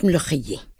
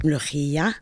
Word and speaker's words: Molokhia: Okay, I pronounce it Molokhia. Okay, and this is due to my regional accent of northern Palestine. Some people in the south, in Gaza and in Egypt, they pronounce it Molokhia.